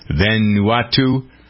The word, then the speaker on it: Vanuatu: There are some organizations calling Cyclone Vanuatu, if that's how we pronounce it, V-A-N-U-A-T-U, Vanuatu.